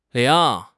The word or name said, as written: ling2